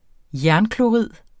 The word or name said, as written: jernklorid